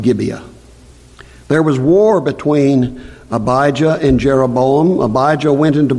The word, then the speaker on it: Abijah: In the 18th year of the reign of Jeroboam, Abijah, in reality that is probably pronounced something like Abiah.